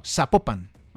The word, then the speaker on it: Zapopan: en Zapopan, con Z, sí. Una municipal. No, Zapopan. – Ah, Zapopan. – Sí. Con acento en la segunda, en la segunda sílaba, en la O, ¿cierto?